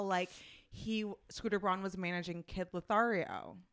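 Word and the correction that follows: Kid LAROI: When I see the name, it looks like Lothario. I'm just you are dyslexic. Like, it's okay. – How do you say his name again? – Kid Leroy. – Kid Leroy.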